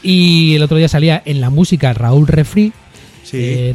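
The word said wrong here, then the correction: Refree: No sé si lo estoy pronunciando bien. Referee. – Bueno, como el árbitro, referee.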